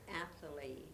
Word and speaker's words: Athalie: Yes, my name is Athalie, that's spelled A-T-H-A-L-I-E, the last name, R-A-N-G-E.